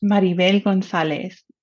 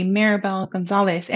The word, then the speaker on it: Maribel Gonzalez: and let me tell you, this is a part of my transformation because before everybody would say my name in an English way, Maribel Gonzalez, and I'm okay with it, right? But I started embracing saying it in my own tongue the way my parents